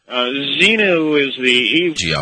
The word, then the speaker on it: Xenu: Does he pronounce it Zenu or Zendu? Up with it, right? His ex-wife. – Right. – Yeah, no, it's crazy. – Zenu.